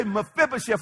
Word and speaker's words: Mephibosheth: Everybody say Mephibosheth. Let's say it together. Mephibosheth.